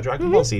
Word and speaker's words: Dragon Ball Z: How do you pronounce this? Because we're talking about the crossover. Dragon Ball Z? Or is it Dragon Ball? – Oh, yeah, Dragon Ball Z is anime.